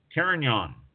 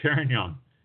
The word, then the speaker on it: Carignan: Carignan.